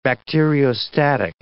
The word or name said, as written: bacteriostatic